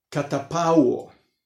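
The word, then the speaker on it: catapauo (καταπαύω): La primera es, a ver si lo puedo decir bien, catapauo, acento en la U.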